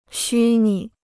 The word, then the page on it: 虚拟: xū nǐ